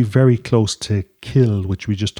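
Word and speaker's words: cill: The Irish word kill, that's C-I-L-L, but it's pronounced kill, and in fact in English you often see K-I-L-L, basically means church.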